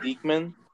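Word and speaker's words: Diekman: How do you say it, Dykeman? – Yes.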